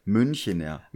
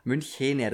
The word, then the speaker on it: Münchener: Vor allem diese Betonung dann immer ganz geil. Münchener. Münchener, das E hm. ist sehr wichtig.